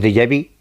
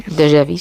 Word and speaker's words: déjà vu: lo que llamamos déjà vu, que eso, mira que bien he pronunciado. – Sí, muy bien, déjà vu, sí.